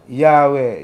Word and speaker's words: Yahweh: That word, am I saying it right? Yahweh? – Yahweh, yes.